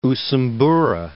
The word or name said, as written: Usumbura